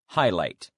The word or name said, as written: highlight